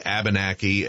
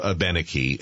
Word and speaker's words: Abenaki: And Chief, while we're talking about the language, most of us say Abenaki. M.A., you used the pronunciation of Abenaki. Is there a right or wrong way when we refer to the Abenaki people? – Well, it's like a potato or potato.